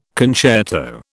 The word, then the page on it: concerto: British English